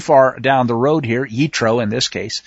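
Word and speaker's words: Yitro (יתרו): Jethro, or now it's NCIS that everybody sees, but in the Hebrew it's Yitro, and it's been pointed out it's spelled more than one way.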